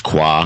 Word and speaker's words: Coi: They just awarded 2 stars, Michelin place called Qua, C-O-I, it's pronounced Qua, in San Francisco, and it's like 11 courses of like a tablespoon of this and a tablespoon of that.